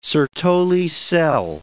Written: Sertoli cell

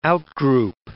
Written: out-group

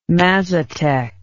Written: mazatec

